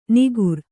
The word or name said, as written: ನಿಗುರ್